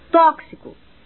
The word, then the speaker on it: tóxico: Tóxico possui seis letras e sete fonemas, pois o X equivale ao som de KS, que se pronuncia mais ou menos assim, XI.